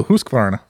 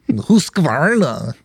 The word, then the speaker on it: Husqvarna: Yeah, that's how you say it if you're Scandinavian. – Yeah, that's sure. I can hear it right now. – Husqvarna. Some Husk. – Stupid Swedish listener being like, well, that's not how you say it. Husqvarna. You gotta like spit somewhere in between like the vowels.